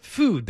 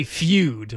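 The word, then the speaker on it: Füde: F-U-D-E with the two little dots over the U like it's German. – Yeah. – They want you to pronounce it food, though. Don't say feud, like a feud between two people.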